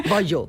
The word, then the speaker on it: Bayeux: Saltaba la noticia en el periódico The Guardian, que dice así: el Museo Británico permitió una visita privada al tapiz de Bayeux, ¿se pronuncia así? – No, digo yo. Yo soy francés, ya sabes que Bayeux.